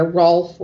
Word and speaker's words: Rolf: It's Ralph, who we've talked about earlier. Rolf. – It's how it's pronounced.